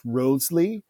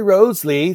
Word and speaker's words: Wriothesley: And there are variations of the pronunciation. I tend to think that at the time it was pronounced Wriothesley.